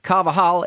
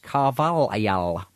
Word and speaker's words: Carvalhal: Thought I would give you your daily dose of facetiousness, nitpickiness, by pointing out that Carvajal is actually pronounced Carvalhal because he's Portuguese.